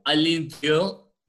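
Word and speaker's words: À l'intérieur: Vi una película que se llama Inside eh, o Alintio, como en su idioma original se pronuncia, que es el francés, ¿verdad?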